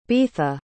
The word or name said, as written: beatha